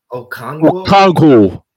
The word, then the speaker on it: Okonkwo: Okonkwo.